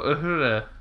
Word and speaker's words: Uhura: Oh, Uhura.